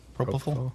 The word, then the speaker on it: propofol: Propofol. – Propofol. – Propofol? – Yep. – Propofol. – Wow. – Yep. – Yep. – That sounds right.